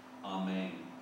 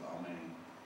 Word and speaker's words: amen: And both are pronounced, interestingly enough, amen. If you pronounce Hebrew or Greek, it's amen.